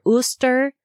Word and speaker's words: Eostre: I should note that Jostr is spelled E-O-S-T-R-E, and so it could be pronounced Eostr or Uster, but the internet told me Jostr, and so that's what I'm going with.